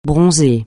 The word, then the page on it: bronzer: francais